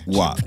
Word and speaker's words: WAP: How do you I would pronounce it WAP. How would you pronounce it? – WAP. – Okay. WAP. Like doing the WAP.